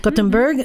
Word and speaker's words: Gothenburg: I'm a 25-year-old engineering physics student in Gothenburg. Did I say that right? – Gothenburg, mm-hmm.